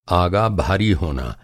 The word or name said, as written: आगा